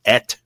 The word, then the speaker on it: et: The word is pronounced et because of Aleph, and then the Tav is a T.